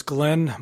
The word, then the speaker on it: Glenn: Mr. Chairman, members of the committee, my name is Glenn Marks. I'm with the Montana Association of Land Trust. Glenn is spelled G-L-E-N-N.